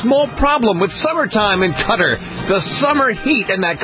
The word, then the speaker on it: Qatar: spelled Q-A-T-A-R, pronounced Qatar.